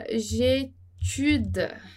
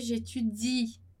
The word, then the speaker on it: j'étudie: Eles esquecem que tem aquele i, né? Mas aquele i, ele tem que ser pronunciado. O que não é pronunciado é o e. Então, a gente não vai falar j'étudie pour l'examen. Não, a gente vai dizer j'étudie. Mas a gente não fala j'étude. Então, j'étudie. A pronúncia ali para no i, né?